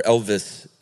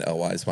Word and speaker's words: Elwes: He continued on his normal ways, and there were three gentlemen named Chris Sarandon, Cary Elwes, or Elvis, apparently, is how you really pronounce it. – Really? – Yeah, I've been saying Elwes my whole life.